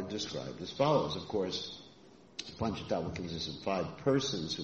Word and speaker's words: Panchatattva: Panchatattva is not the same as Panchatantra. Sometimes people mispronounce that.